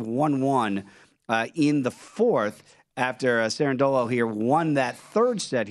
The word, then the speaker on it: Cerundolo: And Cerundolo, did I say it right? – Yeah, Cerundolo.